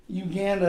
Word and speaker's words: Uganda: Uganda, that's how I say it. That's how the locals say it.